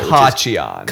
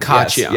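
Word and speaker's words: Khachiyan: I think the emphasis is on the first syllable. – Kachayan. – Kachayan. – Yes, you're right. So it's rape apologist Anna Kachayan. That's the right pronunciation.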